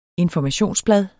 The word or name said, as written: informationsblad